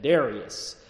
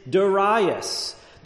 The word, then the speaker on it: Darius: put a little southern draw on it, and it comes out just the way you would expect, Darius. But over in Europe and the European pronunciation, based upon the Greek pronunciation or the Greek rendering of it, is Darius. There is another pronunciation that's very much a mixture of the two, Darius.